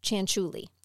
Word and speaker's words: Cianciulli: So when you see Leonarda and you see her last name, it is Cianciulli. It is not spelled like that, but that's what it is.